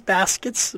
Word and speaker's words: Baskets: My name is Baskets, B-A-S-K-E-T-S.